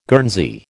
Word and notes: Guernsey: American English

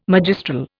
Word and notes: magistral: Indian

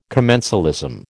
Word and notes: commensalism: American English

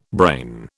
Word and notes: brain: British English

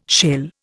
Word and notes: shell: Indian

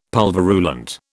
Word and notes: pulverulent: British English

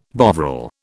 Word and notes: Bovril: British English

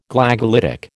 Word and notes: Glagolitic: American English

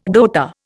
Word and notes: rota: Indian

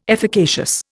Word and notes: efficacious: Indian

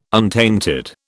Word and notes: untainted: British English

